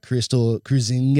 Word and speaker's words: Kruizenga: You'll notice I don't have Beth with me today, but I've got Krystle Kruizenga.